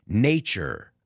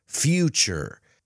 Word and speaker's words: nature: And careful with the pronunciation. Nature. Remember, the T plus the U is ch. Nature. Future. Creature.